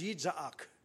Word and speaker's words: Yitzhak: And the name Isaac in Hebrew is an onomatopoeia, because it is pronounced Yitzhak, which sounds a bit like laughing, a bit like a kookaburra, Yitzhak.